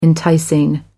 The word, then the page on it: enticing: American English